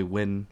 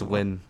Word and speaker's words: Nguyen: It's normally Win, but it's like Nguyen, Nguyen. I don't know. Teachers have butchered it like throughout the year. – What's the proper? Bring culture back into this. – It's Win. Like Win. – Like winning. Like Win.